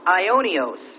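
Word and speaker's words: Ionios (αἰώνιος): Now, the Greek term used in the New Testament to denote eternity is used in 2 Corinthians 4:18, and it's pronounced Ionios. It's spelled A-I-O, and there's a long bar over that O, which is a typical long bar in English, as in hope. And then N-I-O-S, Ionios, 2 Corinthians 4:18.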